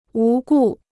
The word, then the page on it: 无故: wú gù